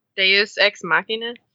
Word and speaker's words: Deus Ex Machina: Yeah, it was definitely Deus Ex Machina. – Yeah, I liked that too. I thought. – Did I finally say it right? – Deus Ex Machina? – Yeah.